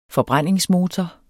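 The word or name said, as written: FORBRÆNDINGSMOTOREN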